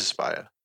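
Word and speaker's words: Aspyr: So that company Asper is making it, or Aspire. I don't know how to say it. – I think it's Aspire. Yeah, it is Aspire.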